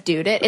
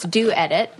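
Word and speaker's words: Dew Edit: This is everybody, Jason included, says Dude It. It's Do Edit. – Oh, okay. – So the scrunchies themselves are called Do, D-E-W, Do's.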